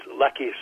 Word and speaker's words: Lache: Lackey Searsill, did I pronounce his name right? – It's Lackey Searsill.